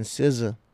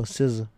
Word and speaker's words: SZA: But on another note, we're gonna get on Doja and SZA. I'm excited about that. – Deadass never knew how to pronounce her name. – Well, now you know SZA. – Yeah. – SZA. Like the RZA.